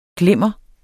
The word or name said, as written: glimmer